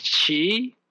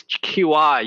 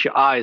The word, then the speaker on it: qi: Okay, pronounced qi. And now, currently it's qi, used to call it chi.